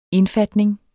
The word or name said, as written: indfatning